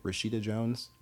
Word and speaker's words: Rashida Jones: Rashida Jones? – Yeah.